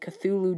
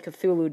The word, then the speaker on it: Cthulhu: English extreme metal band Cradle Filth's fourth album, Midian, features the song titled Cthulhu Dawn, although the lyrics seem to have nothing to do with Lovecraft's sea monster. If you guys are going to have a song called Cthulhu Dawn, you might as well mention Cthulhu.